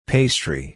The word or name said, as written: pastry